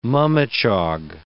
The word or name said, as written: mummichog